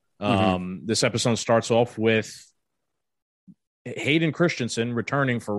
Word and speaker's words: Hayden Christensen: Hayden Christensen.